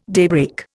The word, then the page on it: daybreak: Indian